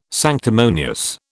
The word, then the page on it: sanctimonious: British English